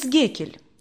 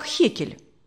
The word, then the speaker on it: Геккель: «Эмс Геккель. Иногда его фамилию произносят как Хекель».